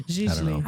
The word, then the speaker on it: Gigli: It looks like Giggly, but I think it's called, the second G is silent for some reason.